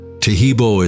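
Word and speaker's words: Taheebo: Tahibo is spelled T-A-H-E-E-B, like boy, O, then the word T, and then the word club.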